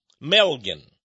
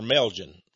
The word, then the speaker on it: Melgen: One of whom is a political donor named Solomon Melgen. Or Melgen, however you want to pronounce it. M-E-L-G-E-N.